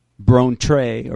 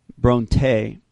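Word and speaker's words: brontē (βροντή): The word, I want you to write this down, for thunder is B-R-O-N-T-E, brontē or brontē, it's how it's pronounced, brontē, and that is 10:27. And the root word is B-R-N-E, excuse me, B-R-E-N-O, Breno, B-R-E-N-O.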